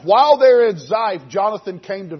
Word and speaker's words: Ziph: They went to the wilderness of Ziph. Is that how you pronounce that wilderness? Good. All right, good. We'll call it that. Looks good. Ziph it is.